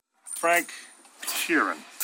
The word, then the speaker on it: Frank Sheeran: Frank Sheeran, I say that right? – Yeah, you said it right.